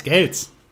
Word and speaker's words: Gales: Und ich muss dir unbedingt ihm seinen Kontakt, deinen Kontakt geben, weil du wolltest ja mal diesen Eric Giles Amp ausprobieren. – Ja, von dir. Gales. – Eric Gales wird es ausgesprochen. Gales ausgesprochen. – Er wird tatsächlich Gales ausgesprochen.